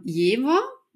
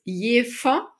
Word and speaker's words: Jever: Jever mit einem W gesprochen. Und sobald man aber hierher zieht und sich einleben möchte und sich integrieren möchte, dann ist das Erste, was einem an den Kopf geknallt wird auf eine norddeutsche Art, dass das Jever heißt und dass das in der Werbung immer falsch ausgesprochen wird.